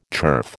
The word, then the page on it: turf: American English